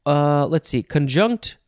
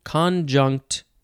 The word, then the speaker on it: conjunct: Conjunct or conjunct, still emphasizing the second syllable.